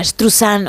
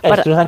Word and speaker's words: Struzan: Bueno, pues Drew Struzan o Struzan o Struzane o como cada uno lo quiera pronunciar, nosotros decimos Struzan Estruzan, que es más fácil.